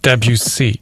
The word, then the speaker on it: Debussy: Who understands the weight of a Beethoven symphony and the nuance of a Debussy prelude. Did I say it right Debussy. – That time? Debussy.